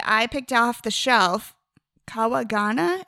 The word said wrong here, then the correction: Kanagawa: Is that how you pronounce it? – Is Kawagawa? There's no N in there. – Kanagawa.